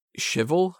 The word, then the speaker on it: Schivell: his name's Victor Schivel, I think is how you pronounce it. S-C-H-I-V-E-L-L. – Yeah, Schivel.